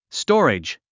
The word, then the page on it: storage: stɔ’ːridʒ; ストーレジ